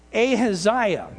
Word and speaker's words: Ahaziah: The problem is that in his zeal, Jehu also attacked the family of Achaliah. I'm not saying that right. Hold on. That's one of those biblical names. It's like, what was that again? Okay, Ahaziah. There we go.